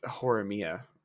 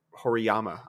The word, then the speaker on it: Horimiya: Uh, I guess with Horimiya, I think that's the first time I've ever said that right. Hor because me and my wife called it Hor Horima Hor, Horiyama. I think is what we called it Horiyama.